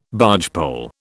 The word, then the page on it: bargepole: British English